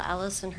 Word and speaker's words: Alyson: It's Michelle Allison Herman. That's M-I-C-H-E-L-L-E. Middle name is A-L-Y-S-O-N. Last name Herman, H-E-R-R-M-A-N.